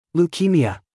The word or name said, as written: leukemia